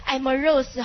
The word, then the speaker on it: amorous: 回头呢，我会陆续教你一两个的哈，比如说 amorous 哈，是什么好色的、淫荡的，是不是？见过那单词吧？ Amorous 看它的发音，爱摸玫瑰，Amorous，玫瑰不是代表爱情吗？